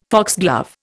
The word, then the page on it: foxglove: Indian